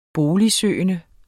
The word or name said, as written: boligsøgende